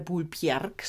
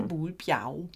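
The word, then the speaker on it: Bulbjerg: Und der Berg, der Bjau, geschrieben B-J-E-R-G, der spricht sich als Au aus, also ja. Bjau. – Und darum ist der Buhlberg nicht der Buhlbjerg, sondern der Buhlbjau.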